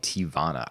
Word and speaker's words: Teavana: I'm contemporarily preoccupied by Teavana.